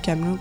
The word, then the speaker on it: Camelot: Golden Sun, qui est un jeu développé par, euh, je sais pas si on dit Camelot, Camelot, je le prononce comme la série.